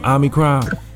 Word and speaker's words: Omicron: Am I saying it right, Milt? Omicron? – Yeah, that's right.